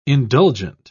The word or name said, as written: indulgent